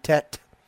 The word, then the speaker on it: Tet: It is a one-syllable word. So both ends of it are trying to get you to say the same thing, tet.